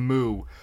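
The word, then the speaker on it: Mu: It's Mew. It's pronounced Mew.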